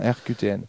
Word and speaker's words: RQTN: Donc ça s'écrit RQTN, comme les lettres, voilà. RQTN.